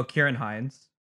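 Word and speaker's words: Ciarán Hinds: Oh, Kieran Hines.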